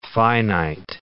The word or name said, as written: finite